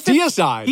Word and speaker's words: Deicide: And it's Deicide.